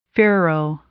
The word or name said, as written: furrow